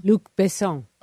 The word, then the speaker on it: Luc Besson: Luc Besson? – Luc Besson.